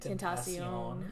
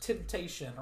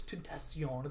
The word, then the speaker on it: Tentacion: Temptation, or whatever, because, no, it's Tentacion. It's got to be pronounced.